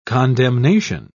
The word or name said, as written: condemnation